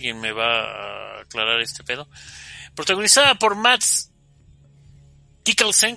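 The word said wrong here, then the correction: Mads Mikkelsen: carnal, ¿lo pronuncié bien? – Es este, a ver, ahorita te digo. – No, Matt Mikkelsen, perdón.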